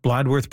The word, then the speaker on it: Bladworth: Am I pronouncing Bladworth properly, Greg? – Yes, you are.